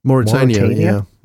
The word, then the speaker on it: Mauritania: This movie, it definitely helps that they filmed it in London, and I hope I'm not saying this wrong, Mauritania? – Mauritania, yeah.